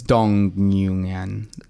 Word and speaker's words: Dong Nguyen: So his name, I believe his name was Dong Nguyen, but I apparently was pronounced that wrong because I did news videos and people got very cross with me. I was going to say, that doesn't sound like it's pronounced correctly. – No, well, it's Dong Nguyen.